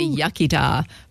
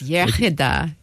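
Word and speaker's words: Iechyd da: The English say Yachida, but it's Yachida, good health.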